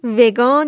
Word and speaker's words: ویگن: به این آدم ها ویگن، ویگان یا ویگن گفته میشه. تلفظهای متنوعی تو فارسی داره و یه تلفظ رسمی یا اینکه ترجمه ازش وجود نداره هنوز. من بهش میگم ویگن چون به تلفظ انگلیسی نزدیک تره.